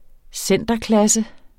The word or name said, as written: centerklasse